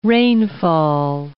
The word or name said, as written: rainfall